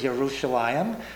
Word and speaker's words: Yerushalayim: You see, what happened to us was the German J never got adequately translated. So the Jordan is not Jordan, it's Yarden. Jerusalem is Yerushalayim. Jesus is Yeshua.